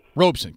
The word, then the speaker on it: Robeson: What about the idea, and I've read it, and I'm not sure I disagree with it, that Paul Robeson, is that how you pronounce his name? – I think it's Robeson. – Robeson, correct.